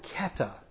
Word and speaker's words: kata: The little phrase "according to" in the Greek is the Greek word kata. K-A-T-A is how we spell it in English. Kata.